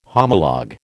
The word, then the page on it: homologue: American English